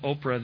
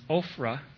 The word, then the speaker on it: Ophrah: This isn't Oprah, the same one you watch on TV. It's actually pronounced Ophrah.